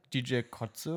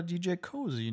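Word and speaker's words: DJ Koze: Wie spricht man den nochmal aus? Ich, ja, DJ Kotze oder DJ Cozy, ne? Ich glaube, das kann man sich aussuchen.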